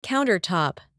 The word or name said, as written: countertop